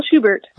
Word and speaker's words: Schubert: Did I say that right? It's not Schubert. – Yeah. – Is that the right way to say it? – Yeah, Michelle Schubert.